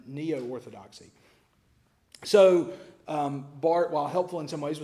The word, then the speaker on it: Barth: Probably the most influential theologian of the 20th century was a German named Karl Barth, B-A-R-T-H, but pronounced Barth.